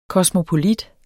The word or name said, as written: kosmopolit